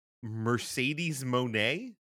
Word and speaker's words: Mercedes Moné: The other thing that we saw this morning is Mercedes Moné.